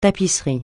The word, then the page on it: tapisserie: francais